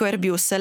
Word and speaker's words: Airbus: Allora, intanto per rispondere a qualcuno dei nostri ascoltatori, io dico Airbus sostanzialmente perché la sede è a Tolosa, in Francia, e quindi dico Airbus. Lei come pronuncia? – Io sono più anglofono, quindi dico Airbus.